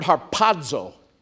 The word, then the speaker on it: harpazo: It's the word harpazo. I love just saying that word. Harpazo. With a sharp P puff. Everybody, harpazo. Accent on the second syllable.